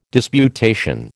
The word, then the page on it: disputation: American English